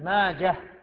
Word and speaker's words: ماجه: ماجه بالهاء ما هو بالتاء، غلط، من ينطقها بالتاء غلط.